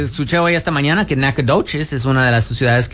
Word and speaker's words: Nacogdoches: Nacogdoches. Así es como se dice.